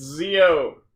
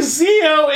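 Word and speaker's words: Zi-O: Legal disclaimer: it's Zio. Why do you keep mispronouncing things? – Because it bothers you. – Are you actually doing it on purpose? – No, that's just how I've always pronounced it. I didn't realize that was a thing. – I say Zio in the show! – What am I saying? – Zio! You're using the wrong version of the letter I.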